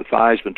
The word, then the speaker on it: Theismann: We think by just simply changing the pronunciation of your last name from Theismann to Theismann to rhyme with Heisman, we can get you that trophy."